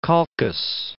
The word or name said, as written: Colchis